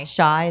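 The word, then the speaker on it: Chi: And yes, Kim wants to call her Shy. That's how you pronounce it. Shy.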